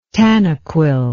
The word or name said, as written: tanaquil